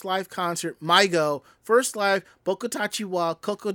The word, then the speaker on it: MyGO: The name is pronounced MyGo, and its pronunciation can be meant a lost child.